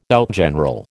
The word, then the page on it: outgeneral: American English